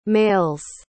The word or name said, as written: males